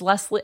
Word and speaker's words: Lesley: It's been a long time since I've gotten a Wesley when I say my name, but everyone spells Wesley with an L-E-Y. And so I'm tempted to say it's Leslie, it's Wesley with an L.